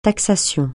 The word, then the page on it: taxation: francais